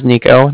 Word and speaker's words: Niko: Okay, again, my name is Nico. That's spelled N-I-K-O. – Mm-hmm. – And the Bank of Cardiff is spelled C-A-R-D-I-F-F, as in Frank Frank.